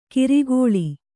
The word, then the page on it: ಕರ್ಕಳ: karkaḷa